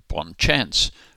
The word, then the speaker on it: bon chance: There's a French expression meaning good luck, which is spelt B-O-N-C-H-A-N-C-E, and we could pronounce that bon chance, but those who use the phrase at all generally say bon chance, which is getting a little closer.